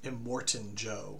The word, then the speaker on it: Immortan Joe: It's Immortan Jim? – Immortan Joe. – Immortan Joe. – Immortan Joe, yes.